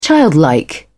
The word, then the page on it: childlike: British English